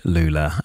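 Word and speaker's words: Loula: And yeah, I'm very excited to see what is next for the band Lula. If you want to follow them online, it's spelled The Band and then it's L-O-U-L-A, The Band Lula.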